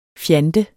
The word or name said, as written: fjant